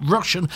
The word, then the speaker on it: Rushen: Patrice Rushen, and not Rushton as I quite often pronounce it, Rushen, forget-me-nots, and do remember to sort out your black bin bags.